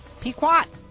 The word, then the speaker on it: Pequot: How do you pronounce that again? Pequot? Pequot.